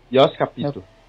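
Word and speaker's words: Jost Capito: Jos Capito.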